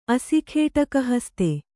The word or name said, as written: ಅಸಿಖೇಟಕಹಸ್ತೆ